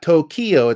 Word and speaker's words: Tokyo: Kyoto, and it's not Tokyo, it's Tokyo.